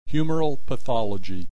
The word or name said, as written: humoral pathology